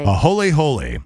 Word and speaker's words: aholehole: A-H-O-L-E-H-O-L-E. And Ahola. – Here's how it's pronounced. – Ahole hole.